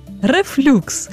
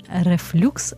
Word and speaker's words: рефлюкс: Рефлюкс. Хоча наголос зазвичай ставлять як рефлюкс, або називають гастроезофагальна рефлюксна хвороба, або гастроезофагальний рефлюкс.